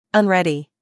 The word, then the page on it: unready: American English